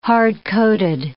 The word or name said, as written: hard-coated